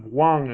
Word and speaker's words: Hwang: Oh, have I been saying it wrong? – That's Huang. Okay. No. Yeah, well, a little bit. Everybody says it differently. It's Alex T. Huang. – Huang. – H-W-A-N-G. – Okay, yes. So the H is silent.